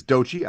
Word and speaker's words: Doechii: This is Dochi. I looked up that pronunciation of that one before the show, featuring Kodak Black.